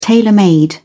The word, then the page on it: tailor made: British English